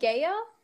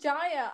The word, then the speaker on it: Gaia: And, like, isn't it Gaia? – Giants and Gaia. – Oh, right. I thought you said Jaya.